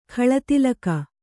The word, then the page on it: ಖಳತಿಲಕ: Kannada